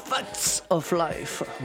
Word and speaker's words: Facts of Life: Ensuite, Facts of Light. – Facts of Life. – Voilà, je l'ai mal prononcé, désolé.